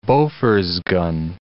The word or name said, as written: Bofors gun